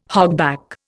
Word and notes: hogback: Indian

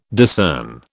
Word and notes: discern: British English

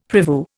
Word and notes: prevue: Indian

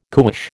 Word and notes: cuish: American English

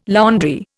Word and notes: laundry: Indian

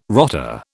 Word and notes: rotter: British English

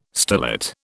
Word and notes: starlet: British English

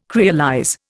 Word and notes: creolize: Indian

